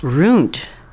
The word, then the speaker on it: ruint: Runt, a Southern vernacular term, and that is spelled R-U-I-N-T, is a reference to having been destroyed by disgrace and often sexual violation.